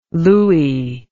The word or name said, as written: looie